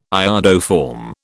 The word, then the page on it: iodoform: British English